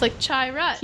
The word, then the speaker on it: Chirrut: It says pronounced Chirrut. The Rut is capitalized. So it's like Chirrut.